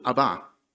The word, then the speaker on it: Abba: It's got the emphasis, actually, apparently, on the last syllable, Abba, which a child would use to their father.